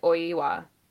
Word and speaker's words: Oiwa: I didn't realize that was Oiwa. I didn't know there was an E in there, or like an E sound. – Oiwa. – Oiwa. Yeah. More syllables than I thought.